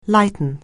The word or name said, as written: lighten